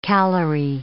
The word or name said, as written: calory